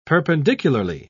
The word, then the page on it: perpendicularly: British English